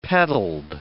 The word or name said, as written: petalled